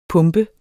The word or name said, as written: pump